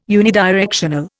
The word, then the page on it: unidirectional: Indian